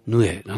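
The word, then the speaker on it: 鵺: ヌエっていう怪物が日本に伝わっているのをご存知ですかね。ヌエっていう字を漢字で書くことなんかないかと思いますけど、昼夜の夜辺に鳥って書いてヌエなんですね。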